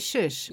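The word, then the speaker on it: Sh!: I don't know how to pronounce it, though. – Shush. – Is it Shush? – Yes. Yeah. – And inside, SH apostrophe.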